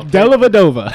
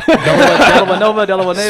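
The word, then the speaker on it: Dellavedova: Delanueva. Let's just go back to that. Delanueva. – Did I say it wrong? I don't even know. – Delavadova. – Delavadova. – Swing and a miss.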